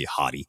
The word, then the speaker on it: Hati: I'm going to have to discover if that is the correct pronunciation, because I don't know if I can keep calling somebody Hati. – I'll tell you what, it is. It is.